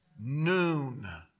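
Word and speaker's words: Noon (נון): 105, Noon. It's pronounced as if it were N-O-O-N. Noon.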